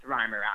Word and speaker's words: Reimer: I'm really glad I listened to your podcast, Alex, before I did this interview because I would have pronounced your last name Reimer if I didn't. – Everybody does. It should be pronounced Reimer, actually. We're the ones who messed up.